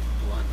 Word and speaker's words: Blunt: Well, how do you say your last name? – Blunt, like you smoke.